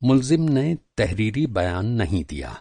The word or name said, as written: मुल्ज़िम